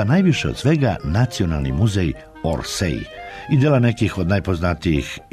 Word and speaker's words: Orsay: Kaže se Orsay, bez J na kraju, a potvrda se može naći u pravopisu srpskog jezika.